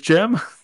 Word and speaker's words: Jim: My name is Jim. It's spelled like the person, sounds like the place.